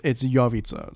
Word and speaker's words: Jovica: In Macedonian, it's Jovica. So like mm-hmm. it's spelled Jovica. – Right. – Jovian. – Soft J's, dude. I love a soft J.